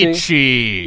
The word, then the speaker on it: Gramsci: Gramsci. – Yes. Yeah. It was a good accent. It was a pretty good accent.